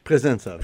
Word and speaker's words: Pressenza: You're with, am I pronouncing this right? Is it Pressenza? – Pressenza. – Pressenza. Yeah. Like one word.